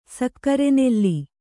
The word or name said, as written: ಸಕ್ಕರೆನೆಲ್ಲಿ